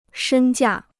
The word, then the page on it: 身价: shēn jià